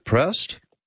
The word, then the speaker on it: Proust: You sent a little note to us, Jay and moi, about a thing called the Proust questionnaire. Is that how you pronounce it? Proust? – Proust. – Proust, I beg your pardon. P-R-O-U-S-T. Proust it's questionnaire. French.